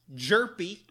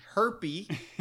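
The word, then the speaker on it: Hjerpe: What you need to know is that it's pronounced Jerpy, not Herpy. – Yes, thankfully. – Jerpy is very loud. It is not silent.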